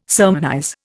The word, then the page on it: sermonize: Indian